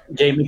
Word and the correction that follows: Jemaine: Clement. – Jemaine, ajá. sí, es Jemaine más bien.